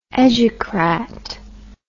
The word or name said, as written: educrat